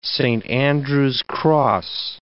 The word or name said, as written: Saint Andrew's cross